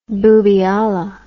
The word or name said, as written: boobialla